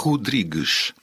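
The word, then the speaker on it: Rodrigues: Pronunția corectă este Rodriguez.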